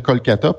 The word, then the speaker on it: Kolkata: Puis même en, passant, on, les gens s'occupent tellement plus de Calcutta qu'on oublie que maintenant, il faut dire Kolkata parce que ça mm-hmm. correspond à la prononciation euh, ah, de, la langue bengalaise.